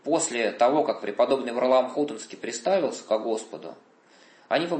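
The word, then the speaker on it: Хутынский: Всю новгородскую землю в свое время осветил своей молитвой, своим подвигом преподобный Варлаам Хутонский. Именно такое делают ударение в его имени.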